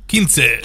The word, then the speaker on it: quince: Is it Quince? Quincy? How did you say it? – Quince. – Quince. – 8453.